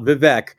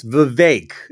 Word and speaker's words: Vivek: So let's all remember, it's not Vivek, it's Vivek, as in rhymes with fake.